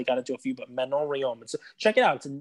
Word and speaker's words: Manon Rhéaume: I know we have a lot of listeners in Canada, so guys, please let me have it because I'm gonna say this wrong because it's a French name, but it's like Manon Rhéaume was her name. That was well done.